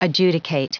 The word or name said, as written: adjudicate